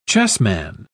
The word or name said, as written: chessman